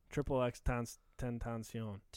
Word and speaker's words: XXXTentacion: XXXTentacion. – XX, what he said. Triple X Tentacion.